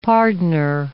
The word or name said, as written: pardoner